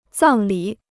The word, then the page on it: 葬礼: zàng lǐ